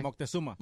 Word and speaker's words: Moctezuma: In 1519, Moctezuma, I think that's how you say it. Moctezuma. His name. – How do you say it? – Moctezuma.